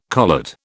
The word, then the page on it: collet: British English